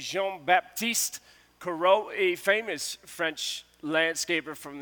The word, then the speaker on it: Corot: At the time, I pronounced it Corot, but that's definitely not it. It's definitely Corot because I threw a Google on it.